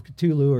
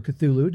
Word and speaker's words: Cthulhu: Certainly Call of Cthulhu or Cthulhu, depending on how you pronounce it.